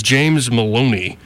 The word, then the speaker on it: Maloney: The plaintiffs, his name is James Maloney. Rhymes with baloney.